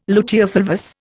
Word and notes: luteofulvous: Indian

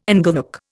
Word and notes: inglenook: Indian

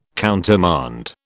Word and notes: countermand: British English

